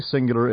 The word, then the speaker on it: Singular: Singular is spelled just like the word, S-I-N-G-U-L-A-R.